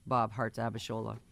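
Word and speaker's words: Bob Hearts Abishola: There's actually a heart in the title. They want it pronounced Bob Hart's Abishola.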